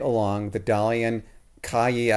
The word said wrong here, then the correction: Çayı: I don't know if I'm pronouncing that correctly, river. – Chaya.